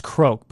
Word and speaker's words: Croke: Okay, so you're saying it's coke. Oh, croak. It's pronounced croak, but it rhymes with coke.